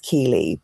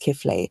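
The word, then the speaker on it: Keighley: It looks like it's pronounced Keeley, but it's pronounced Kifley.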